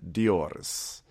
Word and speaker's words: Doris: She's like, what sort of, like, her mom is named Raquel and her daughter's name is Doris, but apparently her name is said Dioris. – Oh, well, it's still Doris. It's spelled Doris.